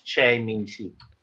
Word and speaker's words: Choi Min-sik: I think his name is pronounced Choi Min-sik.